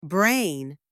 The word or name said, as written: brain